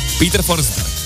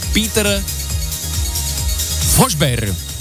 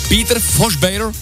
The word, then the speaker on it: Forsberg: Jak byste vyslovili jméno legendárního Petra Forsberga? Peter Forsberg. Správně by to údajně mělo znít Peter Forsberg. Peter Forsberg, protože Švédové dokonce změkčují jména končící na G.